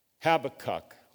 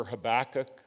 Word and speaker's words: Habakkuk: Habakkuk, or if you prefer Habakkuk, it's pronounced both ways.